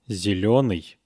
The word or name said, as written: zelenyy